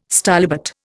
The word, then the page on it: stylobate: Indian